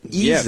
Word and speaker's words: Isaias: Now, they pronounce it Isaias.